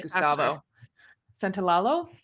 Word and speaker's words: Gustavo Santolalla: Like, it's the same musician too, Gustavo Santolalla. Gosh, I can't say his last name. I probably butchered it. I'm sorry, Gustavo Santolalla. – That sounded better.